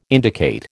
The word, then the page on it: indicate: American English